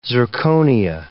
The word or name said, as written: zirconia